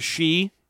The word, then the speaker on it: sidhe: So you've seen this word before, S-I-D-H-E, pronounced she.